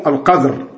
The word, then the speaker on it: Al-Qadr: Avec la prononciation du sukun sur dal, on disons Al-Qadr.